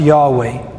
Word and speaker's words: Yahweh: The name of God in Hebrew is spelled Yod Heh Vav Heh, and it's pronounced Yahweh.